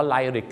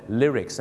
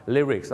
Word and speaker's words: lyrics: ออกเสียว่าไลริกกันเต็มไปหมดเลยเนื้อร้องต้องเป็น lyrics นั่นเองครับถึงแม้ว่าหนังนี้ออกมานานแล้วบ้านเราก็ยังออกเสียงว่าไลริกกันเยอะนะครับดังนั้นครับ lyrics